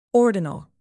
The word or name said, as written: ordinal